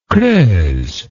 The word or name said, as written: creuse